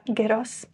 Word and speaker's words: gyros: Do you like a gyros? – I do.